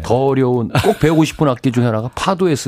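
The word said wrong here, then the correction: fado: 쓰는 악기죠. – 파두 파두. 예, 그래요. 예, 예 발음 예. 좋고.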